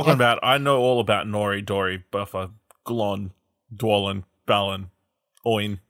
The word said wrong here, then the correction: Gloin: Did you just pronounce Gloin wrong? – I'm reading how I'm reading it, Bomber, Biffa.